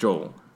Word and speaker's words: Joel: So, it's actually pronounced Joel. – Exactly. – But, you know, I want to promote, you know, especially my Latino heritage. So Joel is really how you pronounce it. Especially my grandparents call me Joel, so I just kind of keep it Joel, you know, two syllables instead of one.